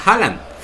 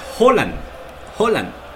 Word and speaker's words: Haaland: que no se pronuncia Haaland, se pronuncia Holland.